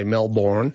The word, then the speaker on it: Melbourne: Although whether or not he pronounced the R is a bit doubtful. The pronunciation of the R in Melbourne. It would have been there originally, but it was on the way out in London English.